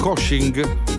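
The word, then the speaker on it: Kösching: Kosching, così si pronuncia in Germania.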